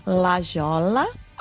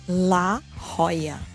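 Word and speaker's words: La Jolla: Muita gente fala Lajola Algumas pessoas falam la rola, mas não é la rola, é la Roya.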